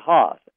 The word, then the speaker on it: Haas: We have Clay Haas, H-A-A-S is how you pronounce it.